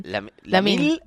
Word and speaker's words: Lamine: Lamil. – Lamil. – Sí, Lamin Yamal. – Lamin. – Lamin. Lamin. Lamine se escribe.